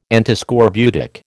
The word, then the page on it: Antiscorbutic: American English